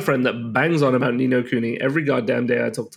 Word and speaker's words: Ni no Kuni: Yeah, Ni No Kuni, man.